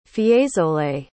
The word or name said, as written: fiesole